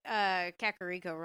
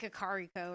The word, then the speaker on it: Kakariko: I used to say Kakariko wrong, so I used to say like Kakariko or something.